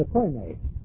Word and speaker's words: Koine: It was written in the Koine. K-O-I-N-E. Long E. Koine Greek.